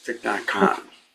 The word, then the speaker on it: Thich Nhat Hanh: Thich Nhat Hanh. – Thich Nhat Hanh. – Nhat Hanh, yes. Thich Nhat Hanh.